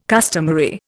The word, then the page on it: customary: Indian